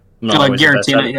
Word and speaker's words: Giratina: Giratina. Am I saying it right? – Giratina, yeah, that's fine.